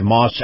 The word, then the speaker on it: Maus: Moss Acura. Moss is spelled M-A-U-S.